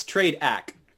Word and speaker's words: TradeAcc: How do you say that? Trade AC? – Yeah, it's TradeAcc. – TradeAcc. – Yeah. The flagship product was the Trading Accelerator, and then that is short for Trading Accelerator, TradeAcc.